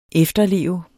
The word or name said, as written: efterleve